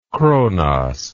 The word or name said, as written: Kronos